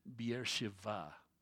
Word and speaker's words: Beersheba: They pronounce it as a V today, Beersheba. B's and V's are often interchanged in the Hebrew language.